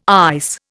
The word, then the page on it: ICE: Indian